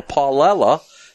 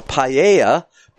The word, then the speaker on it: paella: In episode 176, we have a Paulella instead of a Paella.